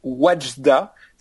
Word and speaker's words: Wadjda: Alors, c'est Wajda. Ça s'écrit Wadjda.